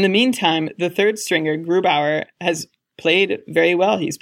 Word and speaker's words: Grubauer: Or do you think that maybe for this season, he's no longer considered the best one and Grobner is the one to grab? – Actually, Ilan, I think it's pronounced Grubauer, but of course, I have my own troubles with that sort of thing.